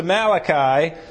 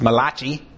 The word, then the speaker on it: Malachi: And then he goes to Malachi, which I have the tendency to pronounce as Malachi.